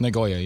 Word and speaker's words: Nagoya: The young self-proclaimed dating scammer from Nagoya, is that right? – Nagoya, yeah.